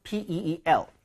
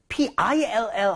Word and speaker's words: peel: 발음할 때 PILL 하면 안 돼요. – 오. – 걔는 알약이죠, 알약. 네. 걔는 입모양도 안 벌리고 그냥 pill. 평소에 하던 것처럼 pill 하면 되지만 얘는 모음이 두 개 섞여 있기 때문에 입모양을 벌려 주셔야 됩니다.